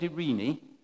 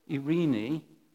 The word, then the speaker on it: eirene: And I had a chat with David earlier, and this word is spelled E-I-R-E-N-E. And I think if you said Irene, nearly right, it'll do.